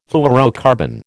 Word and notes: fluorocarbon: American English

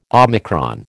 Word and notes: omicron: American English

